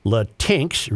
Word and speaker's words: Latinx: LaTinx, recently in vogue, made-up, non-gendered Spanish word, is pronounced just like its gendered counterparts. Latina, Latino, Latinx.